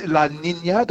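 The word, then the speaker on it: La Niña: Yes, La Nina, Dr. Margo, and La El Nino.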